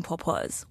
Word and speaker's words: Pawpaw: So you're a caterer and you own a company called Eating Pawpaws. What's that like? – First of all, thank you so much for pronouncing that correctly. Yes, it's Pawpaw, which means grandmother in Chinese.